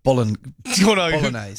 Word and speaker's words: bolognese: In fact, I think you've overpronounced the G there. – Have I? – I think so. Yeah. Bolognese.